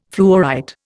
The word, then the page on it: fluorite: Indian